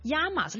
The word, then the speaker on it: llamas: 嗯，¿Cómo te llamas？ Llamas 呢个字咧喺阿根廷咧就会被读成 llamas。 – Llamas and llamas。 – 系啦，咁所以咧你会听到一位西班牙人讲 ¿Cómo te llamas？你叫咩名字啊？但可能一位布宜诺斯 Buenos Aires 嘅人咧，佢就会同你讲 ¿Cómo te llamas？